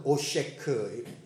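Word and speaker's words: 오쉐크 (עֹשֶׁק): 포악, 원래 그 발음은 오쉐크, 히브리 발음인데 그 뜻은 속임수, 폭력을 사용하여서 나보다 약한 상대를 학대하는 겁니다.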